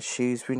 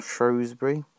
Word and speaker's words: Shrewsbury: I pronounce Shrewsbury as Shrewsbury, not Shrewsbury.